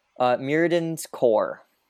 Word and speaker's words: Mirrodin's Core: My second is, I'm going to butcher this name, Mirrodin's Core. – No, that's right. – Yes. You can tap, add one colorless mana to your mana pool. You can tap it, put a charge counter on Mirrodin's Core, or you can tap it, remove a charge counter from Mirrodin's Core, add one mana of any color to your mana pool.